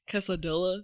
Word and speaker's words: quesadilla: Quesadilla. – Yes. – Quesadilla, yeah. – Oh. – It's how it's spelled. – No. – It is. – Double L makes a Y sound.